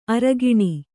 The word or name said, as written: ಅರಗಿಣಿ